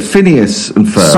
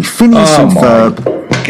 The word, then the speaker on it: Phineas and Ferb: Phineas and Ferb. – Sorry, absolutely. Phineas and Ferb.